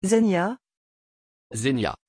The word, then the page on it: Zenya: French